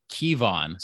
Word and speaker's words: Kevon: So I saw how the Magic tried to phonetically pronounce it in the press hmm. – release, and it's actually Kevon. So kind of like Keon Dueling, it's it's Kevon Harris, I believe.